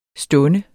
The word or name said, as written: stund